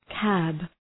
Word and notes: cab: kæb

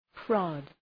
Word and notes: prod: prɒd

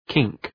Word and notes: kink: kıŋk

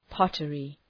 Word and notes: pottery: ‘pɒtərı

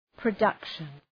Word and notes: production: prə'dʌkʃən

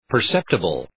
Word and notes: perceptible: pər’septəbəl

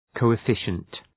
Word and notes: coefficient: ,kəʋə’fıʃənt

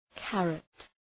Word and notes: karat: {‘kærət}

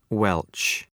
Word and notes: welch: weltʃ

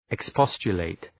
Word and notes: expostulate: ık’spɒstʃə,leıt

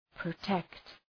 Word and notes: protect: prə’tekt

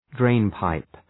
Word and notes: drainpipe: ‘dreın,paıp

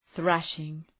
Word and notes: thrashing: ‘ɵræʃıŋ

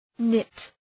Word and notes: knit: nıt

